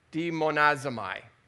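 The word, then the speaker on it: demonazamai (δαιμονίζομαι): And so this word then for demonazio, let me say it properly, demonazamai, it means to be under the power of a demon.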